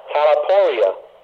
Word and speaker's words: talaiporia: Strong's Concordance 2553, from the Greek word kakopatheo, which is spelled K-A-K-O-P-A-T-H-E-O, meaning to suffer evil. Strong's Concordance 50-04, from the Greek word talaporia, which is spelled T-A-L-A-I-P-O-R-I-A, meaning hard work, hardship, distress.